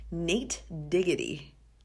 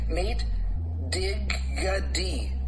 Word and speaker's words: Nate Diggity: I'm here to tell you the way it's supposed to be pronounced. It's called Nate Diggity. See what I did? See? Nate Diggity. D-I-G-A-D. Nate D-I-G-A-D. Diggity. Very clear, right?